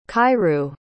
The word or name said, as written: kairu